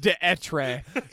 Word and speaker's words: d'être: I think it's pronounced d'être.